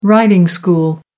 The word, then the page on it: riding school: American English